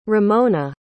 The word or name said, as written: romona